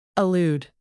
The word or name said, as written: allude